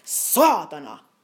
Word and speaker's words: saatana: Okay, then we have saatana. That's like Satan, or S-A-A-T-A-N-A, saatana.